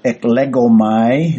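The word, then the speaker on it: eklegomai (ἐκλέγομαι): There's also the noun form, so eklegomai is the way that you pronounce the verb form, and 22 of them, and coincidentally, 22 also of the noun form, so it's kind of split evenly.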